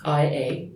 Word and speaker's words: Ia: That is a story of a massive precognitive by the name of Ia, IA. Everybody over here will have fun, will be able to pronounce it correctly. It's short for Iantha, which is Greek, but um, it's no, it's not La, it's Ia.